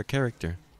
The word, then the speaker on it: character: It's right there, you can see it right there, character. Character. The H there is silent, so it's character, right there.